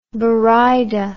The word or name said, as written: buraydah